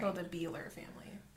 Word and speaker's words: Bieler: How would you pronounce it? – It's spelled like B I E L E R. It sounds. It looks like the Beeler or the Beeler family.